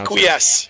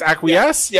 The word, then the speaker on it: Acquiesce: If you listen to, Acquiescence? Acquiescence? I don't know how you pronounce Acquiesce. – It. Acquiesce. Is that what it is? Acquiesce? – Yeah.